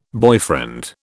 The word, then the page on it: boyfriend: British English